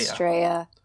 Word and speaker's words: Estrella: Estrella. – With the double L in Spanish. – Yes. – Pronounces a Y.